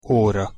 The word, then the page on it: ora: Hungarian